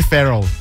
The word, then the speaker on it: Ferrell: Will Ferrell.